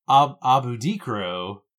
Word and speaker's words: abudikro: A booty crow. Oh, sorry, I put the emphasis on the wrong syllable. It's abudikro.